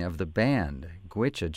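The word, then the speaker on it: Goitse: And the band is called Gwicha, and this comes from their self-titled CD.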